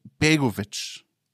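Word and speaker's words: Begović: Also, von allem, was ich weiß, wie man es aussprechen sollte, wäre es Begovic. – Begovic, genau. – Toll.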